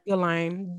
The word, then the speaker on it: Ghislaine: How you say her name? – Ghislaine. Ignore the S.